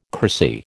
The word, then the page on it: cracy: American English